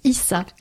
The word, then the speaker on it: Eesah: Bon, ben voilà, hein, si ça vous intéresse, Esa Yasuke, le prénom c'est E-E-S-A-H. – Je crois que ça se prononce Issa, Issa.